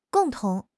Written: gong4 tong2

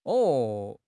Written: 哦

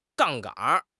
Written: 杠杆

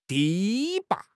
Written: 堤坝

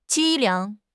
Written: 凄凉

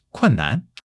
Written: kun4 nan5

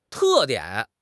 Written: te4 dian3

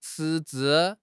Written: 赤字

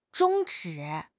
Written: 终止

